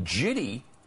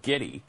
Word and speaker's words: giddy: I mean, he mispronounces so many things like jitty. How does a producer not go to him after the fourth or fifth or sixth time he said jitty instead of giddy and say, Al, the word is giddy, okay?